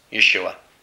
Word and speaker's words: Yeshua: There's no letter J in Hebrew. So if he's speaking in the Hebrew dialect, clearly he's going to say his name, which is Yeshua, Yahweh's salvation.